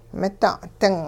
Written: mida'-ding

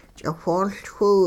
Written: ch'iwhooLchwiw

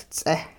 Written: ts'eh

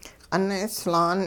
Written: q'un-'islaan

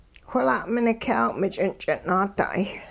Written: whila'minik'yaaw-mich'ing'-ch'in-naada'ay

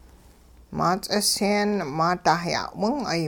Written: maa ts'isyeen maada' ya'wing'ay

